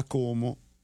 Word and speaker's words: Como: L'hai detto bene, perché lo dicono con la O chiusa. A Como.